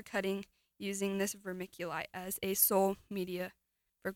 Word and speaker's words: vermiculite: Vermiculite.